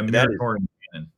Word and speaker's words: Muratorian canon: I don't know if y'all have heard of the, I'm going to say it wrong, but the Muratorian canon. – Yeah, Muratorian canon.